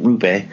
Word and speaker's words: Roope: Roope Hintz played, and apparently I've been pronouncing it wrong because I've been pronouncing it Roope,